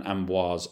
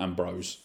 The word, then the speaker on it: Ambroise: I'm probably butchering most of it now, but like, I remember one like Andreas Vesalius and Ambroise Paré, not Ambrose.